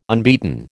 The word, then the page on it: unbeaten: American English